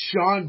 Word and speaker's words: Sean: And that stuff, man, you know, you pronounce Sean, but it's spelled S-E-A-N. – W-G-Y.